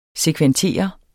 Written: sekventere

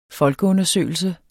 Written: folkeundersøgelse

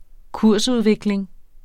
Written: kursudvikling

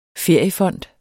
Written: feriefond

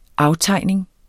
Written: aftegning

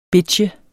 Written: bitche

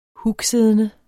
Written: hugsiddende

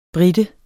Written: brite

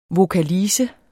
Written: VOKALISE

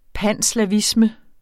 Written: panslavisme

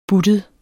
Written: buttet

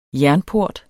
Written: jernport

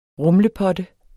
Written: rumlepotte

